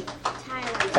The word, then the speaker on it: Tyler: Tyler. – Who? – Tyler. – I spell that. T-Y-L-E.